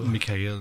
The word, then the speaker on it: Mikhail: Are we allowed to pronounce his name Michael, or is it Mikhail? – Mikhail.